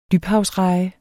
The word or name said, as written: dybhavsreje